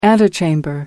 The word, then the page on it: antechamber: American English